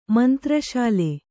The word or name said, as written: ಮಂತ್ರಶಾಲೆ